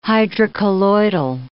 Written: hydrocolloidal

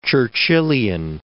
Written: Churchillian

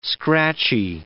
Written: scratchy

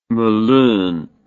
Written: Melun

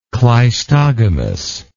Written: cleistogamically

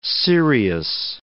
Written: Sirius

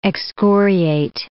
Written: excoriate

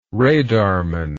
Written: radarman

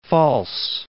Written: False Bay

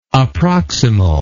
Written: approximal

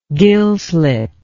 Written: gill slit

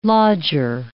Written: lodger

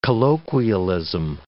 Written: colloquialism

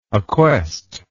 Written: acquest

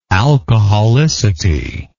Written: alcoholicity

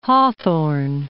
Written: Hawthorne

